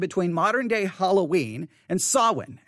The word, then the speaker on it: Samhain: Samhain. – Samhain. All right. Samhain. Now, again, it's spelled S-A-M-H-A-I-N. Samhain. Now, why am I giving you the word Samhain?